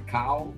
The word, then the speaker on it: cow: Então, C-O-W, L-O-W, C-O-W, cal, L-O-W, low. Então, assim, é, deveria ser cal e lau, então co e low, mas não é.